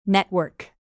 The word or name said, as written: network